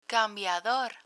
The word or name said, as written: cambiador